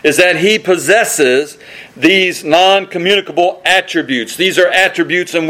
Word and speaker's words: non-communicable: Theologians call these His non-communicatable, that's not how you pronounce it, non-communicable attributes.